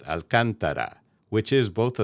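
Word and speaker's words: Alcântara: in the city of Alcantara, located at Brazil's northern Atlantic coast. Those two paraproxetonic names have the emphasis on the third-to-last syllable, in other words, the second one.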